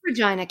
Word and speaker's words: Regina: I love Regina, Canada, because it's spelled like a pizzeria. – Mm-hmm. – But it's pronounced like a woman's body part.